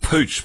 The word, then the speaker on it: Puig: So Estee Lauder it was Puig, wasn't it? Or Puig, or whatever, however you pronounce it. Anyway, that was a deal until it wasn't. – Yeah, I'm told it's actually pronounced Pooch, but there you go.